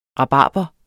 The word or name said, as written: rabarber